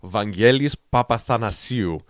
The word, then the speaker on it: Vangelis Papathanasiou: His name is Vangelis Papathanasiou.